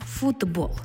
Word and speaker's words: футбол: Щоб закріпити, як працює це правило, розгляньмо ще слово «футбол», яке ми напишемо з літерою «т». «Футбол». Але вимовляємо ми «футбол». Чому в транскрипції звук «д»?